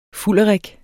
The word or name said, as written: fulderik